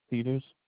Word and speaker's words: Peters: Mark Peters. – Can you spell that first and last name for me? – M-A-R-K-P-E-T-E-R-S.